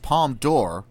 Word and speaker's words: Palme d'Or: By the way, Mike, got a email from a listener here, Isaac, who told us that because it's pronounced Palme d'Or, like door, like a door you open, and not Dior, and that's why the Palme d'Or makes sense and not the Palme d'Or.